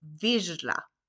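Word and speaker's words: vizsla: Men nu är det ZS och då uttalar man det J. Så man säger alltså virla. J. Virla.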